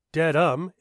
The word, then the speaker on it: Dedham: According to News 25, Boston's only virtual news channel owned by the Atlanta based Cox Media Group, but filmed live in Dedham, which is pronounced Dedham in Greater Boston, Waltham's parents were outraged that a book about killing was on the school's summer reading list.